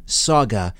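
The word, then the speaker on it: Sauga: Eastern on Saga 960 a.m. That's Saga, as in Mississauga, just west of Toronto. Saga is spelled S-A-U-G-A, S-A-U-G-A, Saga 960 a.m.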